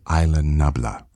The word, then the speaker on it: Isla Nublar: He probably says Isla Nublar.